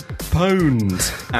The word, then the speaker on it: pwned: Is pwned, as in owned.